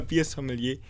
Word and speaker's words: Biersommelier: ein richtiger Biersommelier. Ist das richtig ausgesprochen gewesen? – Tatsächlich. Ja, ja hat mich gerade selber gewundert, aber.